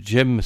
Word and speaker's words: Cem: Je précise que son nom s'écrit Jem, ça s'écrit C-E-M.